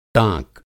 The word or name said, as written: टाँक